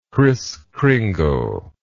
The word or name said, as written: Kriss Kringle